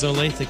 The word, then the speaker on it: Olathe: It's Olathe, Kansas. – Oh, you know somebody that went there? – Tina. She's from Kansas City. Olathe East.